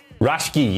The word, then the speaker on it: Raschke: You've got, I'm going to butcher this, Baron Von Raschke. – Raschke, yes.